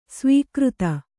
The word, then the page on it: ಸ್ವೀಕೃತಿ: svīkřti